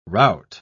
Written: rout

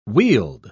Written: weald

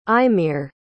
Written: eimear

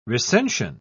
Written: recension

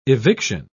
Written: eviction

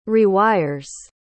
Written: rewires

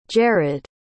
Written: jered

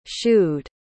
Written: shoed

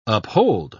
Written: uphold

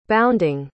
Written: bounding

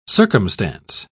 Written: circumstance

